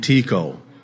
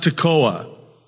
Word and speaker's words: Tekoa: Because I was raised in a small farm town in Washington State that was named Tekoa. It's not pronounced Tekoa. Tekoa. Tekoa High School.